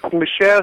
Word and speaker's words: Michel: Okay, Michael Hazanavicius. I think Michel Hazanavicius. Michel, oh sorry.